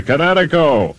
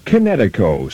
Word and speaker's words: Kinetico: Everybody knows that Kinetico is spelled K-I-N-E-T-I-C-O. – I thought the instructor said Connecticut. Oh, East Coast, son, you should have remembered kinetic as in kinetic energy with an O on the end. Kinetico. – Kinetico. Spell it K-I-N-E-T-I-C-O.